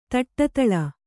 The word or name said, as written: ತಟ್ಟತಳ